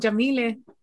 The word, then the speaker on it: Yamile: I'm Jamile. – Oh, that's how you say it. Jamile.